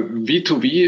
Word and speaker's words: V2V: Ja, äh, V2V ist äh, klassisch äh, englisch richtig ausgesprochen.